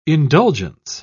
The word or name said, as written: indulgence